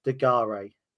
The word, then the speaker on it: De Garis: So correct me if I'm wrong, but this is former Lincoln City loanee Sheffield Wednesday goalkeeper, Paul De Garis.